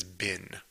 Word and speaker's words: been: which in this case is been. It sounds like bean. Bean would be the way to pronounce it, like in British English, for example. But we just say been in the US. BEEN. BEEN. See?